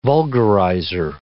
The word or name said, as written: vulgarizer